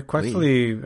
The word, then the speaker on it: Quaxly: I assume it's Quaxly, or like Quack, and then yeah, Quaxly.